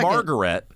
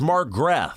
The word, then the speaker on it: Margrethe: So it's not Margaret. It's Margrethe. – Margrethe. – Can you spell that? – M-A-R-G-R-E-T-H-E.